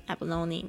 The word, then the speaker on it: abalone: 它有一个 E 的发音 abalone 没错